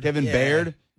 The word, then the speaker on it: Byard: Kevin Baird.